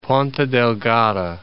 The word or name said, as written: Ponta Delgada